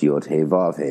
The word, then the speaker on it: Yod He Vav He: The four elements of Kabbalah are encoded in the four letters of the Tetragrammaton, Yod Hebrew reads from right to left, so the tetragrammaton is pronounced yod he vav he, associated with fire, water, air, and earth in that order.